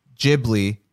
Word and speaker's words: Ghibli: I mean, like, well, what's strange is that like his son recently, Goro Miyazaki, he directed one of the recent Ghibli films, you know, not Hard G.